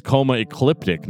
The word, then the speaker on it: Coma Ecliptic: 2015, Coma Ecleptic. Did I say that right? – Yes, you did. – No, it's Coma Ecliptic, not Coma Ecleptic.